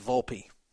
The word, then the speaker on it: Volpe: Oh, by the way, I guess I've been pronouncing Volpe's name wrong. It's Volpe. Sorry. Sorry, Volpe. But that's what Jamie Gold is doing.